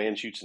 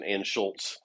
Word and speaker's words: Anschutz: But I won't go so far as to call an Anschutz an Anschultz, because that happens all the time too.